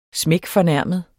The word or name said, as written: klaptorsk